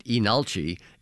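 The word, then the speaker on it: Leenalchi: The first thing I'll tell you is that despite the way you pronounce it, Inalchi, it's actually spelled with an initial L that is not pronounced. So it looks like Linalchi, but pronounced Inalchi.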